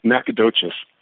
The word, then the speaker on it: Nacogdoches: yes, horribly. It's pronounced Nacogdoches,